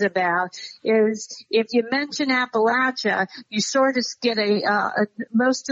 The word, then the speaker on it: Appalachia: Appalachia.